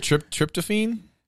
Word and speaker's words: tryptophan: Got a lot of tryptophan sitting on top of the breast now. – Am I even saying that right? Tryptophan? – Tryptophan.